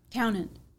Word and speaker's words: Townend: Townend. – Oh, spell that for me. – Like Townend Country. – Okay, Townend. T-O-W-N. – E-N-D. – E-N-D, Townend Country.